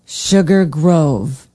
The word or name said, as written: sugar grove